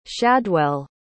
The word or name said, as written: shadwell